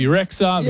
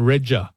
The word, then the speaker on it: Rexha: Is Albanian Guido pronounce it BB Rexha, which is what I think she does in her American world as well. But in Albania, her actual name is Bebe, which means B, and Regja.